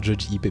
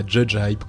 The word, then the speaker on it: Judge Hip: Bref, donc Judge Hip, vous n'avez pas encore entendu l'interview, mais vous comprendrez que la prononciation exacte de, de, ce nom est eh bien Judge Hip et pas Judge Hype comme on le pensait.